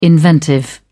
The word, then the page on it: inventive: British English